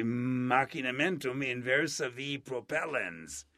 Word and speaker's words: machinamentum inversa vi propellens: How do you say that? – Well, yeah, you say machinamentum inversa vi propellens.